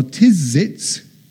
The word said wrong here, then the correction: Tzitzis: am I pronouncing it right, John? Tzitzis. Yeah. These tassels,